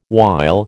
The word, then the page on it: while: American English